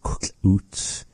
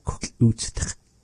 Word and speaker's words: kuk'ut: That one there, maybe for our listeners as a bonus, the word kuk'ut means putting something on. How do you say turn on the light? She said that kuk'ut. – So it's kind of a, if you haven't heard this word before, it's a tricky word, but we'll break it down.